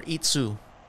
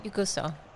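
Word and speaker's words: Ikuso (行くぞ): Ikuso. It's Iku is like a verb to move, and then so means kind of let's do it, so let's go. – Ikuso.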